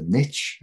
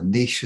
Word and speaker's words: niche: The niche, as you guys call it, or the niche as it's correctly pronounced in French, The niche is twofold, isn't it?